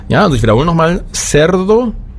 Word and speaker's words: cerdo: Cerdo, das wäre CE. Für Schwein wäre die Übersetzung Cerdo. Cien kennt jeder, 100. Das wäre auch ein Beispiel für CI. Cien, beide Male ausgesprochen wie ein S.